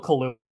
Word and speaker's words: Kaluuya: It's Daniel Kaluuya. It's actually pronounced the same way the alcohol is.